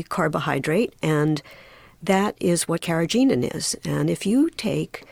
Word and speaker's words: carrageenan: Well, carrageenan, I'm not sure I pronounced that correctly. – You did.